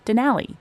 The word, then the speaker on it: Denali: In Alaska, there are also different opinions about how to pronounce Denali. Outsiders usually say Denali, while locals tend toward Denali. They both come from the Koyukon Athabascan name for the mountain, Denali.